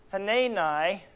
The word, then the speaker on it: Hanani: Hanani, that's the proper pronunciation of that.